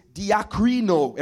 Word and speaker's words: diakrino: The word there for weigh is diakrino. Everybody say diakrino. Diakrino. It's spelled D-I-A-K-R-I-N-O.